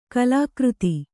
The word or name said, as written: ಕಾಲಕೀರ್ದಿ